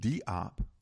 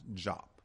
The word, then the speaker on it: Diop: A man that we must know and know of and look at him and look at his work is Dr. Sheikh Anta-Diop, although many people pronounce it Diop because it's spelled D-I-O-P. It's pronounced Jop.